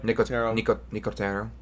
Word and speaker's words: Nicotero: The main executive producer was Greg Nicotero. Is that how I'm saying that right? – Nicotero.